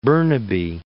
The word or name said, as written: Burnaby